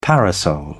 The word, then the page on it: parasol: British English